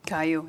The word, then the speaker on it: Caillou: How do you pronounce the that bald cartoon character kid? Is it Caillou? – Caillou. – Yeah. Caillou. Yep.